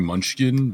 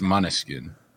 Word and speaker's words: Måneskin: Some people say it's supposed to be Munchkin, but it's Måneskin.